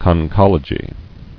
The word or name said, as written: Conchological